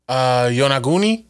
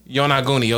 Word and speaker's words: Yonaguni: Yonaguni. – Yonaguni.